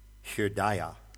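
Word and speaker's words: Hridaya (हृदय): I suppose the rigorously correct pronunciation of the word heart in Sanskrit would be Hridaya. H-R with a dot underneath it, D-A-Y-A.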